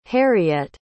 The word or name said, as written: harriette